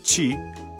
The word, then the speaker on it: ち: This is a little more difficult because it includes two irregular syllables.Pronounced chi, not ti, and tsu, not pronounced tu.